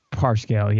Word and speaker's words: Parscale: Brad Parscale, is that how you say his name? Parscale? – I think it's Parscale, yeah.